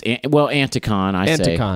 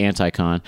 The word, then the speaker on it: Anticon: Is that how you pronounce it? – Yes. Anticon. But you could say Anticon.